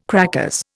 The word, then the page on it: crackers: Indian